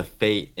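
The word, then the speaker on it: fete: Okay, so the Baron orders a fate in Fade's honor because he cannot let the people leave with their energies unspent.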